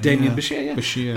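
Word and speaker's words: Demián Bichir: That's how you pronounce his name. – Damien Bashir, yeah.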